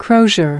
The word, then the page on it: crozier: American English